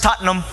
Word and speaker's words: Tottenham: But if you're a true fan of Tottenham Hotspur, you would say it like the English say it, like tater tots and going numb. Put those two words, Tottenham.